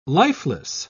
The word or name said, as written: lifeless